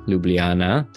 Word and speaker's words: Ljubljana: Well, today I'm pretty excited because you went to the capital city of your home country of Slovenia, Ljubljana. Did I say that right? – Perfect. It was perfect.